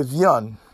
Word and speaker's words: viande: We say viande. It's the same thing with French.